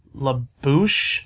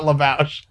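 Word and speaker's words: La Bouche: Number six, Be My Lover by LaBouche. – Yeah. – I keep wanting to pronounce that and say LaBouche. It's not LaBouche. It's LaBouche.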